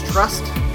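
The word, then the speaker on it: TR/ST: Are you sure it's pronounced that way? It's not Trust. – It's trust.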